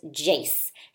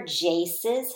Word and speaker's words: Jace: So Jace has a long A. J-Ace. Does it rhyme with case? Do they end the same way? J-Ace. K-Ace. Yes, they end the same way. So case, Jace, and vase all rhyme, and they all have the long A sound.